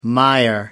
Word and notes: mire: American English

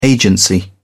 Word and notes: agency: British English

